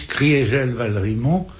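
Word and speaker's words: Kriegel-Valrimont: On prononce Maurice Criégel-Valrimont pour marquer que en 18, l'Alsace a cessé d'être germanisée.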